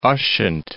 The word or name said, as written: Ushant